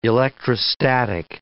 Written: electrostatic

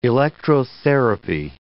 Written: electrotherapy

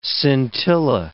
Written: scintilla

